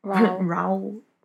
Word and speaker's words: Raoul: Raoul. Like growl. Raoul, growl. Right, the final masquerade. So it is spelled R-A-O-U-L, Raoul.